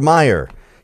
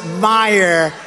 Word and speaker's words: Meijer: One of the 10 impeachers, a guy who spells his name M-E-I-J-E-R, but they pronounce it Meyer.